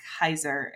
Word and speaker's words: Keyser: So if you just search my name, Molly Kaiser, it's like spelled like Keiser, K-E-Y-S-E-R.